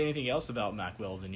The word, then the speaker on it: Mack Weldon: It rhymes with Wack Speldon. – Jack Feldon. – Yeah. – Is it? – No. It's called Mack Weldon.